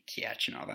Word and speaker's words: Khachanov: It's going to be some very nice shot making for Khachanov. It's Khachanov.